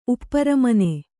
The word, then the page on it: ಉಪ್ಪರಮನೆ: kannada